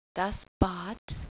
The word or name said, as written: Bad